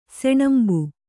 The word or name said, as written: ಸಣಂಬು